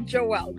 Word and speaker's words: Joelle: Like Noelle, Joelle, yeah.